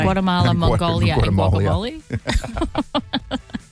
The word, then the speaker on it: Guatemala: Is it, how do you say it? – Guatemala.